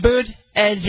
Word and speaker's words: Buttigieg: Boot, edge.